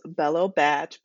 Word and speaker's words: Bello Bach: So it's actually pronounced Bello Batch. Batch is short for bachelorette.